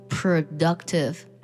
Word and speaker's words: productive: productive 需要特别注意的是 POR 当中的 O 的发音有变化它不读作 pro